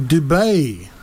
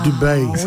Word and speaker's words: Dubé: But it's pronounced... – but it's not Dubé. – Dubé. Cool. – Fair. All right. – He's originally from France.